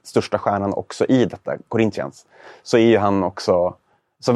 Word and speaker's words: Corinthians: Spelade i Fiorentina, framförallt känd för sin tid i Corinthians. Där han var det var ett riktigt dåligt uttal. Corinthians kanske? – Corinthians. Jag vet, jag vet, portugisiska, ja.